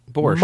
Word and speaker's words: Borscht Belt: Am I even saying that right? Borscht Belt? How do you say it? I never. – The Borscht Belt? – Borscht? – Borscht.